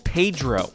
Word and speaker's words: PEDro: Anne started a project, about 2 decades ago called Pedro. No, not the character from Napoleon Dynamite. We're talking about the Physiotherapy Evidence Database. That's how they say it in Australia.